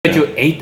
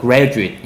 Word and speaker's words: graduate: graduate 아니고요. graduate 이렇게 발음하셔야 됩니다. 대학 맞아요.